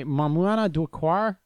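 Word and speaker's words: Maïmouna Doucouré: a French film written by and directed by Mamouana Doukkar. That's how you say it.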